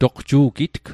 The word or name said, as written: toqju'gitg